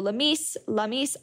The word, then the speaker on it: Lamees: And don't forget to follow Lamise Attar-Bashi, that's spelled L-A-M-E-E-S.